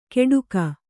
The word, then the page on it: ಕೆಡುಕ: keḍuka